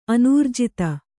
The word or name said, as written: ಅನಿರ್ಜಿತ